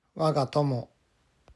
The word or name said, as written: Waga Tomo